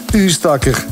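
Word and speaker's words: Oostakker: Maar niet voor Lieve de Koning uit Ustakker. Dag Lieve, goedemorgen. Zeg ik dat goed? – Goedemorgen. – Zeg ik dat goed, Ustakker? – Absoluut, absoluut.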